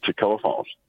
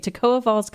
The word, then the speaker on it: Toccoa Falls: Is that right? Toccoa Falls College? – Toccoa Falls. – I got it right. Yay. Okay. Toccoa Falls College.